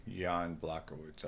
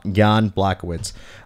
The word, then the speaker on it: Jan Blachowicz: his takedown defense up against the cage Ian Blachowicz. Am I saying that correctly? – Jan Blachowicz, I believe. Yeah, Jan Blachowicz.